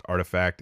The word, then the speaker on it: RTFKT: Nike bought virtual sneaker designer RTFKT, pronounced Artifact, in December.